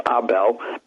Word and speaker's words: Abel: In South America, his name is pronounced Abel, but anyhow, he did a lot of early work on Hitler living in South America, and he was an excellent, excellent journalist.